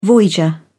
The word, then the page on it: voyager: British English